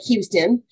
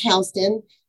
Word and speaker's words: Houston: I live in Houston County, which looks like Houston, but it's pronounced Houston.